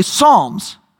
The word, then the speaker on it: Psalms: The P at the beginning of Psalms is silent, right? We know this. It's a silent letter. It makes no sound, as we say, Psalms.